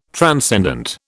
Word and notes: transcendent: British English

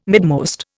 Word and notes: midmost: Indian

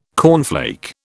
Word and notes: cornflake: British English

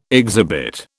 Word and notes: exhibit: British English

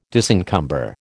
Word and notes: disencumber: American English

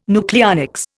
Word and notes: nucleonics: Indian

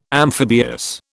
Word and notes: amphibious: British English

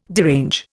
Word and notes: derange: Indian

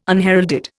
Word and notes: unheralded: Indian

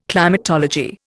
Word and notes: climatology: Indian

